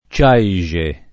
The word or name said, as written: Ciaixe